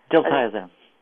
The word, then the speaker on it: Diltiazem: It's a drug called, it's unpronounceable, diatazem or something. – Diltiazem. – Yeah, okay, that sounds good.